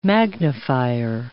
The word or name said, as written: magnifier